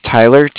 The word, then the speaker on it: Tyler: My name is Tyler, T-Y-L-E-R. – Okay. – And I'm calling with Cardiff. It's spelled C-A-R-D-I-F-F, as in Frank Frank.